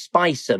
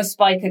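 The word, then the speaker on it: Spica: I'm always Spicer myself, sort of thing. It doesn't mean to say I'm into the spice of life or anything like that, but it's way out pretty people pronounce it, isn't it? The star names are a bit of a nightmare, aren't they? – I'm a Spiker girl myself.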